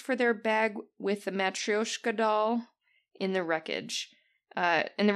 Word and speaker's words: Matryoshka: Matryoshka.